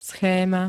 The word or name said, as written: schéma